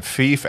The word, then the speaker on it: Fife: It's pronounced Fife. – Fife. – Life. You don't ask thief.